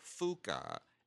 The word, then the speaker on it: Fuuka: I want to pronounce that correctly because I'll get in trouble if I don't. It's Fuka, Fuuka. Don't mispronounce it.